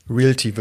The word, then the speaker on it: Realty: Realty wird es ausgesprochen, ja.